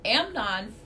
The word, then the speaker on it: Amnon: Amnon.